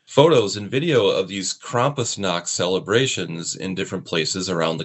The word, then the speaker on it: Krampusnacht: What I love about the other things that you do is they're so odd and weird, because I came across the Milwaukee Krampusnacht. I think that's how you say it. – Krampusnacht, yes.